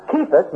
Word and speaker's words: Kephas: comes from Kephas, and is pronounced Kephas, meaning a stone.